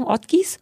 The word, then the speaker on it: Ortgies: wie wird denn das ausgesprochen? Ortgies? – Ja.